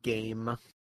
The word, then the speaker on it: Gaim: You pronounce it game.